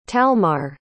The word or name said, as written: talmar